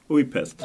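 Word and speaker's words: Újpest: Vad gav det halvåret i Upjestosha, om jag uttalade rätt? – Ujpest. Ja.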